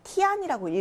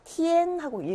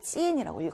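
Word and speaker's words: tian: 자, 다음은 IAN 예외 발음이었죠. 이안이라고 읽지 않고 이엔이라고 읽는다고 했어요. 그래서 티안이라고 읽으면 안 되고 티엔하고 읽어줘야 되는 겁니다. 자, 우리 앞서서 짜이 찌엔 할 때 찌엔도 이렇게 썼잖아요. 이거 읽을 때도 찌안이라고 읽지 않고 찌엔이라고 읽었어요. 그래서 얘도 티엔이라고 읽습니다. 트어 발음과 이엔 발음이 합쳐져서 티엔이라는 발음이 나는 거죠.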